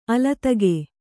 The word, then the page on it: ಅಲತಿಗೆ: alatige; noun